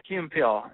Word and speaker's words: Key and Peele: Yeah, Key and Peele.